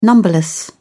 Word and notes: numberless: British English

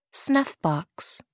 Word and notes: snuffbox: American English

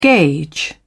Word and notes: gage: British English

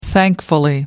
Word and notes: thankfully: American English

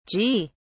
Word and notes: ji: British English

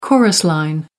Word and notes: chorus line: American English

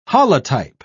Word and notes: holotype: British English

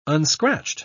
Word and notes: unscratched: British English

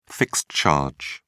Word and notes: fixed charge: British English

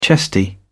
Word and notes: chesty: British English

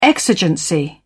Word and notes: exigency: British English

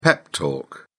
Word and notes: pep talk: British English

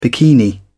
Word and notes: bikini: British English

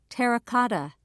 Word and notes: terra cotta: British English

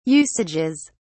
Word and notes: usages: British English

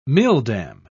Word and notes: milldam: British English

